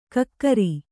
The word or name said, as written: ಕಿಕ್ಕಿರಿ